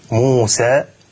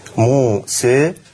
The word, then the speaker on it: موسى: فمثلا عندنا كلمة موسى في بين القراءات خلاف في قراءة الالف، في كيفية قراءة الالف، فهناك الفتح موسى اسمه الفتح، هناك التقليل أو الإمالة الصغرى موسى، وهناك الإمالة الكبرى موسى.